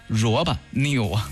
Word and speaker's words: RoboNeo: 因为它是英文 ROBONEO，萝卜 new。